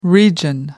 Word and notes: region: American English

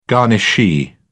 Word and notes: garnishee: British English

